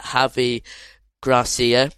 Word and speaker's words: Javi Gracia: The first game under new manager Javi Gracia.